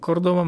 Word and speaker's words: Córdoba: I rzeczywiście, jeśli wybierzecie się do, Kor do Kordoby, Kordoby, bo Kordoba ma akcent na O.